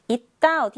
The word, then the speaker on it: 있다: 그래서 있다에서의 있 은요, 디귿 받침이 있는 잇, 이렇게 소리가 나요. 그 다음 역시 이 다가 아니라 뒤에 디귿이 왔을 때에는 쌍디귿으로 소리가 납니다. 그래서 있다 라고 읽어야 해요. 있다 어디에 있나요? 있다 아니고 있다. 이렇게 이어줍니다.